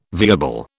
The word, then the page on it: viable: British English